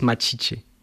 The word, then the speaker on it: Machiche: Alors, je sais pas, je ne suis pas brésilien, mais je crois que cette pièce se prononce Machiche.